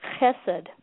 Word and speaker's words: chesed: And we're going to look at the Hebrew translation of charity, which is called chesed. And chesed is spelled C-H-E-S-E-D in the English transliteration.